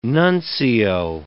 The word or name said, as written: nuncio